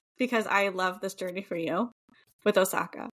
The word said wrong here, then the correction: Ahsoka: Did I say it right? – Ahsoka.